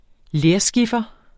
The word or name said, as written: lerskiffer